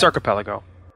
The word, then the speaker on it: archipelago: So I'm playing as the Egyptians, and I did have a randomly generated map type, and it ended up being kind of an archipelago or archipelago. How do you say that? – That's archipelago. Archipelago.